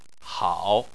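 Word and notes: hao: tone 3

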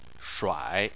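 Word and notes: shuai3: tone 3

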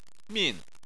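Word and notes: min4: tone 4

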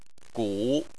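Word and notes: gu: tone 3

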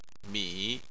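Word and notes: mi3: tone 3

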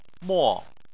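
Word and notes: mo4: tone 4

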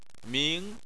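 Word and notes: ming2: tone 2

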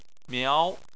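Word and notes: miao2: tone 2

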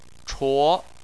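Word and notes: chuo: tone 2

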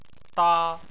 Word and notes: da: 1

